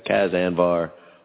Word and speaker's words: Cas Anvar: So thank you once again, James Kyson, Cas Anvar. Well pronounced, by the way, I think. – You're good with Canadian names. – I am horrible on Canadian names, so I'm. – You nailed it.